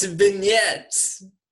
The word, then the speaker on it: vignettes: See, vignettes. Which kid vignettes? It's vignettes.